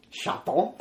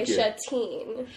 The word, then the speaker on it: chaton: What so if you say it with a French accent, Chaton. – If you want to say it with an American accent, you would say Chatine.